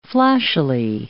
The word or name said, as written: flashily